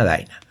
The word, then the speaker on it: Daina: Así que se escribe con I latina como suena Daina.